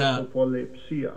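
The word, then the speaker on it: prosopolepsia (προσωποληψία): So the word there, let me give you a pronunciation of the word, here's prosopolepsia. – How, there you go. – Prosopolepsia. – There's the pronunciation of that word, and it simply means a respect of persons.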